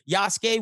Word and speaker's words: Yasuke: I always got to do it that way because that's how his name is pronounced. Yasuke.